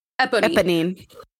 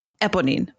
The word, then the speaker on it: Eponine: Is it Eponine? – Eponine. – Eponine.